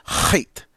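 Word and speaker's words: Chet (ח): So it's, as I mentioned when I was introducing the Psalms in the early shows of Beacon of Truth, that in Hebrew there are letters that are glottal stops. So we don't have those in English. But in Hebrew they do, and so you have to really pronounce the letter from your throat. So it's chait.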